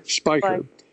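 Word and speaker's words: Speicher: Why don't you tell us about Captain Scott Strecker? Is that the way to pronounce his name? – Spiker.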